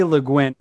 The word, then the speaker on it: Le Guin: Le Guin.